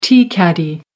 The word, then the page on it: tea caddy: American English